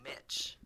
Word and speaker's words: Mitch: I've introduced my people or introduced myself to people, and I'm like, "Hello, my name's Mitch," and they go, "Mitch." No, I'm like, "No, Mitch." – Mitch, like Mitch. Mitchell. – Like, oh, I thought you said Match. – It rhymes with like, bitch.